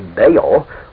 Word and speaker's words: Baal: Usually this was the Hebrew word Baal, in English spelt B-A-A-L, and usually pronounced Baal.